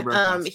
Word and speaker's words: Burakovsky: Burakovsky. – Burakovsky.